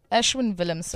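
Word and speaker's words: Ashwin Willemse: Ashwin Willemser. Ashwin Willemser. Yes, yes, yes, yes, yes. – Man, he's your people. Get his name right. – Ashwin Willemser.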